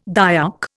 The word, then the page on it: Dayak: Indian